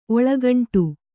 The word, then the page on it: ಒಳ್ಗಂಡ: oḷgaṇḍa